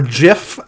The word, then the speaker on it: GIF: Um, I don't know if I saw like a GIF or something, uh, or GIF as uh, it should be properly pronounced.